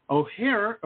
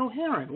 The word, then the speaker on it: O'Hearn: Mr. Tim O'Hearn. No, I'm saying that wrong. It's O'Hearn.